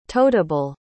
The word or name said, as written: totable